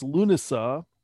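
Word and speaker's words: Lughnasadh: Another word that is commonly used in the pagan community is lunasa, which is spelled with a variety of extra consonants. – It's pronounced lunasa, lunasa, neither of which are correct pronunciations for Irish.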